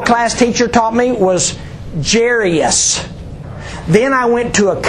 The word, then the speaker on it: Jairus: But he said you pronounce it Jairus.